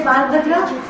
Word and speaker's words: Balabhadra: Balabhadra. Not Bal. Who said Balabhadra? – Sorry.